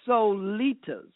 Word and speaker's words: Solitas: His name, she taught me how to say this, Solitas, S-O-L-I-T-A-S.